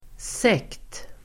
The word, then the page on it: sekt: [sek:t]